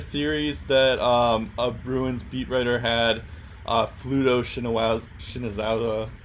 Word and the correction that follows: Shinzawa: I forget how to pronounce his last name, but Pluto Shinzawa. – Shinzawa, yeah. He writes for the Athletic.